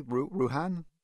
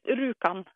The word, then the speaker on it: Rjukan: First off, am I pronouncing the name of the town correctly, Rjukan? – Almost. It's Rjukan.